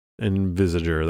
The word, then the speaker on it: Envisager: How do you say the chapter name? You say it first. – Envisager. – That's what I said too, so...